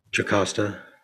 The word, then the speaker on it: Jocasta: But her, once she takes the action, they take, is it Acosta? Is that how you say her name? – Jocasta. – Oh, they use the IO spelling in mine. – That's the anglicized. – Jocasta, got it. – The anglicized pronunciation of Jocasta.